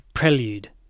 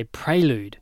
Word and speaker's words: prelude: I would say prelude. – Yes. – So I think some people might say prelude. Maybe that's an American one. They say prelude is incorrect. The proper pronunciation is prelude.